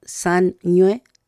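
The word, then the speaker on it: sañwe: Ahí también, sañue, que es como suena, pero se escribe con la letra ñ y la W, que es ese sonido, ¿cierto?, que suena como una doble N, pero que no es una doble N.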